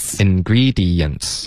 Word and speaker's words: ingredients: OK, 很多的学生会把后面两个这个 dents 发成一个音节，就变成 dents, 就变成点。可是它的发音是 dents, 一定要分开。 – 好。 – OK?ingredients, 四个音节。 – ingredients.